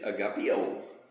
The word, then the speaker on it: agapeo: And it's spelled A in the English, A-G-A-P-E. There's another one that is A-G-P-E-O, which is agapeo.